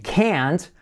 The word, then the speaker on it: can't: He can't hold, y ese can't, la T no se pronuncia, simplemente se acentúa toda la palabra.